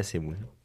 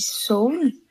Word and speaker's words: Seoul: Que d'ailleurs, j'ai appris, on dit pas Séoul. C'est un truc français. On dit Seoul. C'est juste que c'est écrit E-E-O, mais en fait, ça se prononce O.